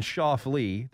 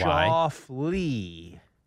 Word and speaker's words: Schauffele: And then Cantlay and Schauffele, the two most mispronounced names in all of golf now. Cantlay. – L-A-Y. – Schauffele.